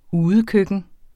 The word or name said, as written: udekøkken